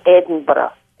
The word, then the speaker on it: Edinburgh: Edinburgh? – Uh-huh. – Edinburgh. – The Scottish way to say it is Edinburgh. – Edinburgh. – But most Americans call it Edinburgh.